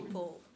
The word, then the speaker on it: Powell: Powell, P-O-W-E-L-L, but it's pronounced Powell.